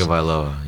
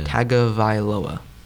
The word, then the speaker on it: Tagovailoa: Tagovailoa. Yeah. – Tagovailoa.